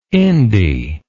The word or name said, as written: indy